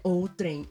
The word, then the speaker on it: outrem: Gente, eu tô morta que a pronúncia é outrem. Eu sempre lia mentalmente, outrem. – Outrem. Não, outrem só em Minas.